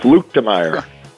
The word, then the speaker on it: Luetkemeyer: Luetkemeyer.